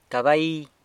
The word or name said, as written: cabai